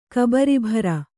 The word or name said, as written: ಕಬರೀಭಾರ